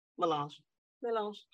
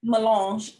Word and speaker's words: Mélange: Mélange. – Okay. Mélange, a red wine.